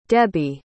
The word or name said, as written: debbie